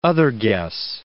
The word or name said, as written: otherguess